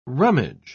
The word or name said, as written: rummage